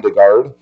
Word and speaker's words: DeGarde: Okay, so it is good to see you this morning, and my name is Ron DeGarde, and I have to say my name slowly. If I say it fast, it sounds like Rhonda Guard, and I'm not a woman preacher.